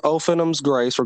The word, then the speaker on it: Ophanim's Grace: okay, so it's called Ophanim's Grace.